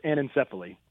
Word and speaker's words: anencephaly: I'm not sure I'm going to pronounce this right, but anencephalia, is it close? – Yeah, you're in the ballpark. It's anencephaly.